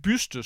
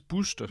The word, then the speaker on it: byste: Ja, og så en helt underlig, særlig udtale af byste, som hun mente burde udtales buste.